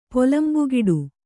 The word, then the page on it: ಪೊಲಂಬುಗೆಡು: Kannada